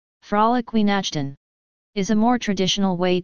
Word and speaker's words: Weihnachten: Well, the W Nachten. Is pronounced as a V. – Weihnachten.